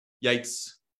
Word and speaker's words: Yates: Yates.